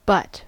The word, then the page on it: butt: American English